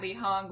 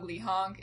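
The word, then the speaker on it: Leehong: So, it's at Lehong, Lehong. It's L-E-E-H-O-N-G, L-E-E-H-O-N-G. There's no difference there, just my last name twice.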